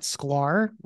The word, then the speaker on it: Sklar: Like you said correctly, my name is Brett Sklar, rhymes with Sklar.